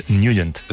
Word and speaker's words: Nugent: Hombre, siempre lo hemos llamado en el barrio Ted Nugent. – Claro, yo era de Ted Nugent. – Pero la pronunciación correcta es Nugent.